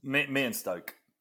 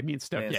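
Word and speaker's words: Meonstoke: Meenstoke. – Okay, Meenstoke.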